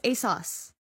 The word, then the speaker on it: ASOS: Most of the clothes in Bat that you see are actually bought on, ASUS. I don't know if I'm saying that right. – ASOS? – ASOS? – ASUS. – It's an O. Not a U.